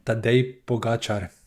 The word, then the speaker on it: Tadej Pogačar: Tadej Pogačar.